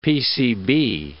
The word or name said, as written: PCB